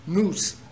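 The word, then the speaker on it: nous (νοῦς): We pronounce it N-O-O-S, nous.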